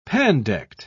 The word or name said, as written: pandect